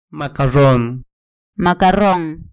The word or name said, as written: macarron